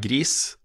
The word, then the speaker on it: Gris: Um, og jeg har også gått på nett og sjekka hvordan man uttaler det navnet, og det er gris. det er gris, ja.